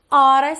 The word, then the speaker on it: oughta: Deixei até uma dica aí de pronúncia. Pode pensar em ora, como a gente fala que horas são. I oughta study more.